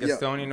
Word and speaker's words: Gastonia: Gastonia, North Carolina, pretty hard.